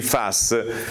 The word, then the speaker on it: PFAS: È successo che nel medio ponente della nostra regione, in provincia di Savona, è stato riscontrato nelle acque potabili un concentrato di PFAS, si pronuncia così, si scrive PFAS, di PFAS, superiore a quello che dovrebbe essere.